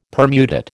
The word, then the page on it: permutate: American English